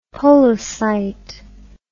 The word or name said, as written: polocyte